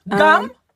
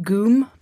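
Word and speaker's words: goom (ГУМ): Gum? – That's right. It's pronounced goom, but close.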